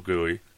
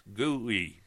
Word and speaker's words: GUI: You gotta graphical user interface, GUI, G-U-I, GUI. Trust me, you'll see people spell it GUI too. A lot of times, like goo, G-O-O, then E-Y-E.